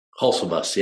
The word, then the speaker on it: Hulsebus: Hulsebus, yeah.